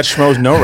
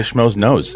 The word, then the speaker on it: Schmoes Know: And you got Schmoes No right. You didn't say Schmoes Nose.